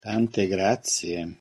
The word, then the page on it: tante grazie: Italian